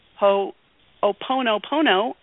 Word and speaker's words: Ho'oponopono: And when I found Ho'oponopono, I said it right.